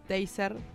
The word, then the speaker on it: Taser: sobre el tema de de las pistolas taser, o teaser, no sé bien cómo se dice, no, O taser. Taser. Taser. Yo, taser, sí.